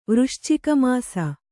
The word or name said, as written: ವೃಶ್ಚಿಕಮಾಸ